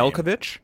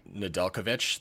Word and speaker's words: Nedeljkovic: Nedeljkovic. – Nedeljkovic, thank you, is playing out of his mind.